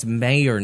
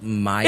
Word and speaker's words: Mayer: It's Mayer, not Meyer.